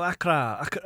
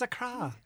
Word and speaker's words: Accra: Okay, the cheapest thing I could find was Aer Lingus and Royal Air Morocco for €700 to Accra. Accra is the capital of Ghana. But there's a few other things. – I would have thought it was Accra now. – No, Accra.